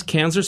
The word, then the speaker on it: Kanzler: Yes, Kanzler spelled with a K and a Z, not an S.